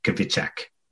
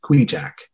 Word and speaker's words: Kvichak: We fish on the Kwee-Jack River, which is actually spelled K-V-I-C-H-A-K, Kwee-Jack, if you look at it, but pronounced Kwee-Jack. It's a native Alaskan name, which is why it has the unusual spelling.